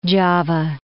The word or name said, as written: Java